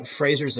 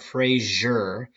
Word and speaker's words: Fraser: Other examples, the Frasers of the phrase. Now, I was corrected on this pronunciation. That's not Fraser, as we tend to pronounce it in the United States. It's Fraser, Fraser, Fraser, I think.